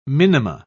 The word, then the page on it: minima: British English